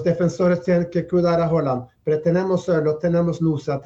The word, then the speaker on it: Haaland: Es decir, Haaland, vamos a pronunciarlo como se pronuncia en Noruega, digamos, ahí es la figura del arquero, pero hay toda una estructura de equipo fuerte, ¿eh?